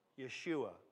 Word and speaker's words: Yeshua: Yeshua, or as we see here, Joshua.